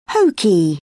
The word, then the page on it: hokey: British English